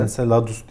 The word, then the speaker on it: Enceladus: NASA'nın Cassini aracı Satürn uydusu nasıl okunuyor? – Enceladus diye okunuyor herhalde.